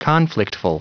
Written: conflictful

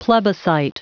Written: plebiscite

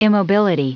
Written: immobility

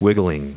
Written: wiggling

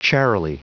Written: charily

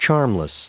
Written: charmless

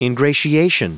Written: ingratiation

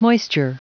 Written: moisture

